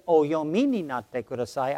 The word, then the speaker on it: oyomi ni natte kudasai: But so I tried, but instead of saying, oyomi ni natte kudasai, I said, oyome ni natte kudasai. I mispronounced it. And oyomi ni natte kudasai means, please read this. But oyome ni natte kudasai means, please be my bride.